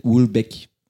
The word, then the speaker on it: Houellebecq: Also, der Name wird so ausgesprochen: Michel Ulbeck.